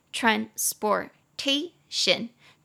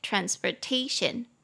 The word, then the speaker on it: transportation: 运输工具就叫做 transportation，四个音节，tran t r a n sport s p o r t t a tion t i o n transportation，重音节在第三音节 transportation。哦，中间那个 sport 啊，这个是英式发音会发 or，但是如果是美式发音的话，你会直接发 er，所以美式发音是台湾人比较听常听到的，所以我再念一次，会念成 transportation。